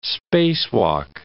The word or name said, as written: spacewalk